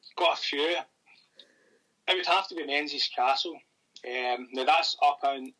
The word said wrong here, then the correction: Menzies: I've said Menzies. It's actually pronounced Mingus Castle.